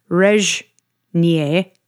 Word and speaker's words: Régnier: Looks like Régnier, but it's pronounced Régnier.